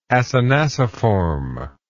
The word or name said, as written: acinaciform